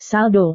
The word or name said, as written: saldo